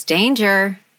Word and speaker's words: Danger: It's Danger.